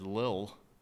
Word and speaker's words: Lil: I mean, how I've heard it said is Lil. – Yeah. – Lil. Not little. Lil. – Lil? – Lil. Nas. – Nas. – Okay. X. – Lil Nas X.